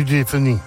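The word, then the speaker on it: Dauphiné: hvordan man udtalte. Kriterium de Dufini. – Ja, du Dufini. – Er det rigtigt? – Ja, Kriterium okay.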